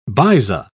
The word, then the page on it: baiza: British English